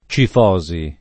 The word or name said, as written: cifosi